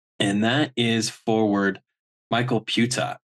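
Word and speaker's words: Pyyhtiä: His last name is spelled P-Y-Y-H-T-I-A, and somehow you get Puta out of that.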